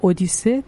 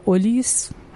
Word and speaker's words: اودیس: اودیسه تماما درباره سفریه که اودیس یا اولیس هر جور که تلفظ بکنید چون اینا تلفظ های یونانی و تلفظ لاتین